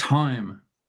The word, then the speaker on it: thyme: In my herb garden, in my herb garden I have parsley, dill, basil and thyme. – Yeah. – Okay. Yeah, the H is silent there.